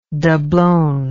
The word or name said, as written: doblon